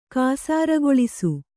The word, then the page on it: ಕಾಸಾರಗೊಳಿಸು: Kannada